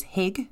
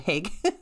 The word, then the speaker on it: Hygge: It is spelt H-Y-G-G-E, so in my head for a long time it was Hig. It's a Scandinavian word. It is Hygge.